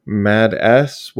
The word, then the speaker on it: Mads: So we've got Mad S, which I am assured that is how it's pronounced. It's just spelled Mads, but it is on Shudder.